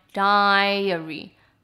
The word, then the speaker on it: diary: 那如记法呢，我们就可以看到 diary，因为它的发音是 di 在前面，所以就是 di diary diary，乳制品的 ai 则是 a dairy dairy。